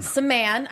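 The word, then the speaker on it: Samman: We're going to talk a little bit about Josh Salmon. – Saman. – I even wrote that out phonetically.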